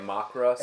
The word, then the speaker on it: makkara: Makara sausage? – M-A-K-K-A-R-A something.